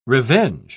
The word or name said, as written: revenge